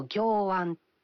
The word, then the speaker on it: 暁闇: そしてこちらは、私の思い出の日本語は暁闇という言葉です、暁の、皮変の暁ですね、暁のあと闇、暗闇の闇と書いて暁闇、これ音読みだと行暗とも読むそうですけれども、長女が不登校になった時、夫が教えてくれました。